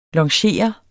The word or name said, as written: longere